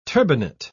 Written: turbinate

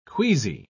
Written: queasy

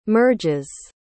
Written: merges

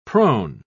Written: prone

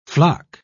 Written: floc

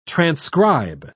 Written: transcribe

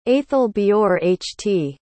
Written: aethelbeorht

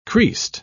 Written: creased

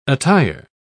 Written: attire

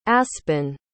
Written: aspyn

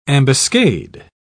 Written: ambuscade

